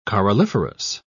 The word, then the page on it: coralliferous: British English